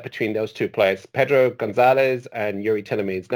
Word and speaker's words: Gonçalves: Yeah, it looks like Gonçalves. Okay, I'm big on my pronunciation these days. But it's actually Gonzalez. Gonzalez, You don't pronounce the V, and the C with the little number five on the bottom is like a Z or like an S sometimes, but Gonzalez.